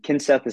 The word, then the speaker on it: Kinseth: And Kinseth is spelled like kind Seth, and it's Ryan with an Awesome.